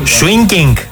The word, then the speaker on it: swinging: Δεν είναι swingy, είναι swinging. Δηλαδή, ξέρεις τώρα, πρέπει και την προφορά ρε παιδί μου.